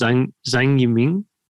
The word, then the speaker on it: Zhang Yiming: Is it Zhang Yiming? – Yeah, basically.